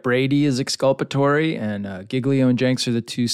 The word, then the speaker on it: Giglio: And this is a motion to compel, is it Giglio? Am I saying that right? – Yeah, you got it. Giglio.